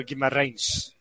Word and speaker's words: Guimarães: Like, there's only one Bruno in the league right now, and that's Bruno Guimarães. – He said it right. Nailed it.